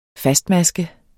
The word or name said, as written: fastmaske